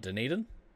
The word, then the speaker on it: Dunedin: So Dunedin is how you pronounce that? I've actually always been curious about that. – What, Dunedin? – Yeah.